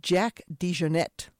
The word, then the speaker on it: DeJohnette: That's Where or Wayne from Jack DeJohnette. Let me get that name a little bit better pronounced. Jack DeJohnette. How about that? That's a little bit cleaner.